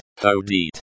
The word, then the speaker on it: ходить: Pronounced ходить.